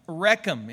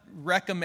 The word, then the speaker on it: Rekhem: The word is Rechem. It's spelled R-E-K-H-E-M. Rechem. It's not Rechem as in what our kids did when they were really small and wrecked everything in the house.